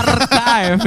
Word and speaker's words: archive: Ini bacanya arsip kan, arsip. – Archive? – Aduh. – Bener enggak? – Bener enggak, teman-teman? – Salah. Yang bener apa dong? – Iya, bener sih. – Betul, archive ada sih, tulisannya sih bener sih. – Archive, yes.